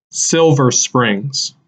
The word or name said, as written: Silver Springs